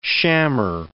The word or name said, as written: shammer